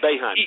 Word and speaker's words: Boeheim: His head coach initially, Jim Boeheim, I believe is how it's pronounced. – Yeah, Boeheim, Jim Boeheim, Boeheim.